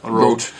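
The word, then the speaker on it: Roth: They we've got to pronounce Rote correctly. Rote.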